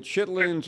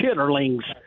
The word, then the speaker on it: chitterlings: Am I correct in saying that chitlins are the intestines? – Is correct. Okay. They're pig intestines. Right. Chitterlings is the correct. That's the way Yankees would pronounce it.